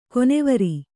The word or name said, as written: ಕನವರಿ